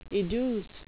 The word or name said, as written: educe